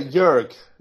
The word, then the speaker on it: Jörg: See, I was going to say Jörg, and I said no, nobody ever pronounces the J's. – Actually, we say Jörg. So German say Jörg Michael.